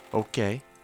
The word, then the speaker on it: hoquet: Hiccup, we say, somewhat fatuously, that this name is an echo of the sound, yet, strangely, the French idea of the same sound, written H-O-Q-U-E-T, is pronounced okay, and the Spanish idea, written H-I-P-O, is pronounced ipo. We also spell our term H-I-C-C-O-U-G-H, though without affecting the pronunciation.